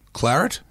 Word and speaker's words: claret: Claret, is that how you say it? Claret? – Yeah.